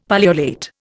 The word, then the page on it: pullulate: Indian